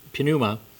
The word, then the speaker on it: pneuma: And if you you actually pronounce the P in the Greek, pneuma, we get our words like pneumatic and pneumonia from this for the word breath, lungs and so on.